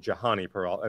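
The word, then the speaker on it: Jhonny Peralta: So he spelled it J-H-O-N-N-Y, and I would pronounce it Johnny Peralta. I mean, it wasn't Johnny Peralta.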